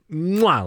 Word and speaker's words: MUA: It's a released MUA, its first product, or mwah, like you said, is the correct pronunciation.